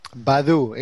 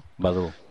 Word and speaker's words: Baidu: Badu, ese, ¿no? – Baidu.